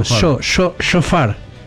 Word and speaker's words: shofar: Se escribe S-H-O. Sofar es un cuerno que, bueno, lo usan... S-H-O, Shofar.